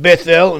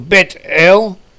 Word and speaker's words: Beit El: It's called in English transliteration Beth El, in modern Hebrew Bet El, in biblical Hebrew possibly spelled pronounced as Beth El.